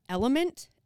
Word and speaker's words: LMNT: Electrolytes, we've done ads for Element. L-M-N-T is how it's on, that's how it's spelt for their logo.